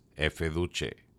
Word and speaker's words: Efeduce: my contacts there have confirmed that the brand name is indeed intended to be pronounced in Italian as Efeduce.